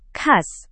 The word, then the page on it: khas: Indonesian